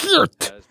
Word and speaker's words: Geert: So it's more like a Geert.